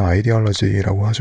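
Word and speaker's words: ideology: 영어 발음으로 이데올로기는 아이디얼러지라고 하죠.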